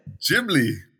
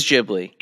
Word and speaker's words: Ghibli: What's actually the right way to say it? – Ghibli. – It is Ghibli.